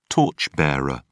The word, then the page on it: torch bearer: British English